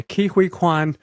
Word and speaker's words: Ke-Huy Quan: Ke-Huy Quan, welcome to the program. So nice to talk to you. – Wow, thank you. And by the way, you said my name perfectly.